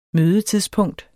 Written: mødetidspunkt

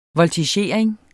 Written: VOLTIGERING

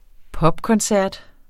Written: popkoncert

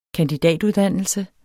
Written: kandidatuddannelse

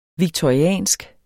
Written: victoriansk

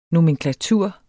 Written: nomenklatur

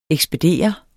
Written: ekspedere

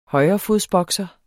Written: højrefodsbokser

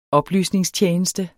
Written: oplysningstjeneste

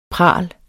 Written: pral

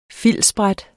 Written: filtsbræt

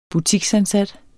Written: butiksansat

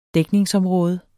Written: dækningsområde

